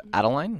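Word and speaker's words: Adaline: Opening this week in theaters, The Age of Adeline. Am I saying that right? – Yeah.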